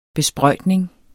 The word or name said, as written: besprøjtning